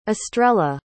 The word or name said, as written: astrella